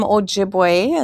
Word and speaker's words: Ojibwe: So I am Ojibwe, and that's how it's pronounced.